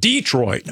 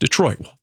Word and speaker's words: Detroit: we have to endure Wolf calling it Detroit all the time. – Oh, putting Detroit. – The emphasis on, it's just on Detroit. – Detroit. Detroit. It's just Detroit.